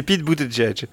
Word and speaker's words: Pete Buttigieg: pardon, ouais, pardon je, peut-être mal prononcé. C'est Pete Buttigieg.